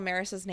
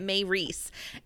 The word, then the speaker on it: Maryse: I was like, I feel like I remember listening to one of the audibles or something where someone kept pronouncing it May Reese, and it just it always looks wrong to me. Her name is weird. I think it's cool, but I have to weird. look in the book every time I like uh-huh. say spell her name be- because I can't. I want to spell it M A Y R S E, uh-huh. even though it's Mary Se.